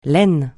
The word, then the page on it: laine: francais